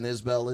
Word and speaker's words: Isbell: Do you know who Jason Isbell is? – No, but I think that that is the correct pronunciation.